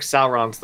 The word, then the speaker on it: Sauron: So when I was growing up, I saw it, whenever I saw it spelled out, I would read it like dinosaur, so Sauron. – Yeah. – And I've heard it pronounced both ways, but I think Sauron's the correct one.